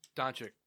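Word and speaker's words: Dončić: Luka, how do I say his last name? – Doncic.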